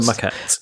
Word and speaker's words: Maquette: The other game that I started this week, I'm about halfway through, is the Marquette, I believe it's pronounced. – Oh, Marquette. Yeah.